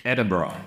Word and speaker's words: Edinburgh: Edinburgh, ja.